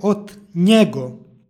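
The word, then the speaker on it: od njega: U stihu 13. u posljednjoj reči izgovor je od njego. Krajnja reč jeste u stvari zamenica, lična zamenica trećega lica. Kada ispred lične zamenice stoji predlog, između predloga i lične zamenice dodaje se n koja se obavezno jotuje, stoga je od njego.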